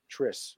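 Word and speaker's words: Tris: Do you even know how to pronounce this? – No clue. – T-R-I-S. I should have looked up the pronunciation. – Triss. Wow, I'm an idiot.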